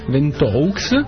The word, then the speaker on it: Ventoux: Mont Ventoux, Ventoux, la mia pronuncia francese è quasi migliore della tua australiana, credo, che era la Laura.